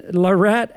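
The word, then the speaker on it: La Ratte: Anyway, that was Ain't No Fun by Larate, L-A, and then R-A-T-T-E. That is pronounced Larate. It's French, but they are not French.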